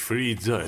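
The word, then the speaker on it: Frida: خب با لباس پوشیدن مثل هوریدا میخوای چی کار کنی ها؟ – هوریدا نیستش، فریدا تلفظش، مثل فری فری انگلیسی. فریدا فریدا کارلو گرفتی؟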